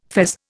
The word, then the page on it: fez: Indian